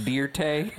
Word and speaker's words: Dirt: It's pronounced Deer Tay.